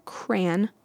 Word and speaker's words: crayon: The reason for the crayon, as in cranberry, pronunciation is because of the influence of the French derived crayon, which is pronounced by the distinct nasal vowel sound.